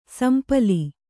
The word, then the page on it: ಸೊಂಪಲು: sompalu